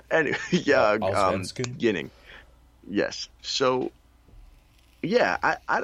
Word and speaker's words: Allsvenskan: You know, he bounced around a little bit between the SHL, the Super Elite, and the Allsvenskan. That's how they say it. Allsvenskan.